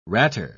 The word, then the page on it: ratter: British English